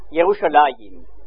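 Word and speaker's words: Yerushalayim (ירושלים): En hébreu, on prononce Yerushalayim, ce qui signifie en français la ville de la paix.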